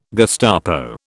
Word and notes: Gestapo: British English

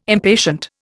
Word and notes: impatient: Indian

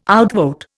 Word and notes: outvote: Indian

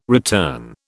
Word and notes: return: British English

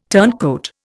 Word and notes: turncoat: Indian

